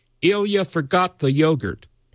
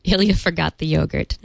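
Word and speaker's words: Eyjafjallajökull: And Leo was playing the correct pronunciation by an Icelandic person the other day, and you're ahead of the game to not look at the spelling and just say Ilya forgot the yogurt.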